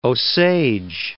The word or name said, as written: Osage